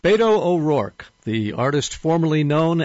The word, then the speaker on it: Beto: Some people have been funning with his name this week and calling him Beto O'Rourke, I guess because it rhymes with veto.